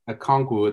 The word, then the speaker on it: Ekwonu: Ekwonu. It's kind of like Okonkwo from Things Fall Apart, but with.